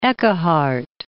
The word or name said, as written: Eckehart